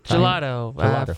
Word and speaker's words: gelato: Why don't you give Stefan a big shout out and tell people how they can find out about his amazing, is it gelati? Am I saying it right? – Gelato.